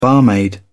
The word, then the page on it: barmaid: British English